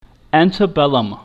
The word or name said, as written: antebellum